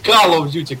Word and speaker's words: Call of Duty: Правильно говорить Call of Duty, как говорил Озон 671 Games.